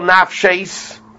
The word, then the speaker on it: nafshis: Rabbi Kiva goes by the way it's pronounced. You pronounce it plural, nafshis, and therefore it teaches even blood of two dead bodies is tamei.